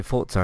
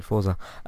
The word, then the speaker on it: Forza: I actually said it right for a change. I didn't say Forza, I said Forza.